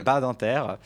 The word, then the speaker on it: Badinter: on va le faire à la France, Badinter.